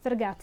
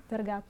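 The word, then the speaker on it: Fargeat: di Fer- Fergat si dice, Fergat.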